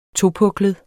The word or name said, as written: topuklet